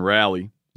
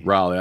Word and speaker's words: Raleigh: Am I saying that right? – Raleigh. – I know they're real particular about that. Raleigh. And the co-director of the Genetic Engineering and Society Center. – You got it.